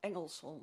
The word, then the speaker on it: Engelsholm: En u zegt het wordt makkelijker om dan via dat Eggersholm, zeg ik dat goed? – Ik woon op Engelsholm.